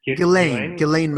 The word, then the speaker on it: Ghislaine: Ghislaine Maxwell. Yeah. – Ghislaine Maxwell.